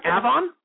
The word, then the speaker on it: Avon: Yeah, all right, so coming up next here, we've got our second featured guest, Mr. Walter Beck from Avon, Indiana. This guy is he's an Indiana boy. Avon, am I pronouncing that right? – Yep. – Dr. Quinn, yep. Avon? This guy, Avon. It should be Avon.